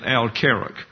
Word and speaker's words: Al-Karak: But this is where they would link up with the present-day city of Al-Karak. I think it's accented on the first syllable, is an Arab word.